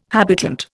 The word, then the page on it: habitant: Indian